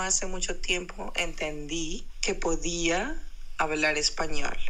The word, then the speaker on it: podía: Podía, then it's podía, P-O, podía.